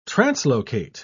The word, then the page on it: translocate: British English